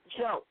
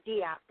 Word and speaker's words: Diop: Not Diop. It's not Diop. It's Chope. – Spell what you're saying. – It's the same thing you're saying, but you're pronouncing it wrong. It's Chope, not Diop.